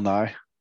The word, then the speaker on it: Nighy: Bill Nye.